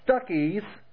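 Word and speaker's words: Stachys: Yes, that's the way it's pronounced. Stuckey's. Now, I think of Stuckey's as that place that has nice candy chewies, you know, on the road. Stuckey's. But literally, that's how I pronounce Stuckey's.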